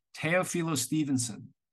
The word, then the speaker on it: Teofilo Stevenson: Teofilo Stevenson. And he's like, okay, Teofilo Stevenson. Okay, I'm ready. Camera's on. Teofilo Stevenson was the greatest heavyweight of my generation.